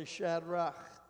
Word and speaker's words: Shadrach: S-H-A-D-R-A, it's actually Shadrach is how you say it.